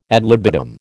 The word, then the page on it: ad libitum: American English